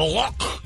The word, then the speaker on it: Loch: Lake, their choked out gross way of saying the word lake. Over there, the Loch. Oh God, we clear your throat and say it like a human.